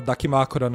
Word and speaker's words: Dakimakura: Ah, o Dakimakura, né? – É, eu, é, falei errado o nome, desculpa.